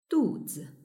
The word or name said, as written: duzi